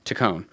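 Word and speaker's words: Taccone: Tacone.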